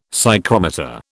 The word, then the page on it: psychrometer: British English